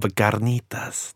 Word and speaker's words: carnitas: Det är, men, nog mina favoriter är nog alltså refried beans, carnitas och så härligt uttal då, carnitas. Det låter lite som så här mexikansk carnita, come here. – Ja, det låter som att jag försöker säga det på... – Nej, du säger det bra, alltså det är ingen diss.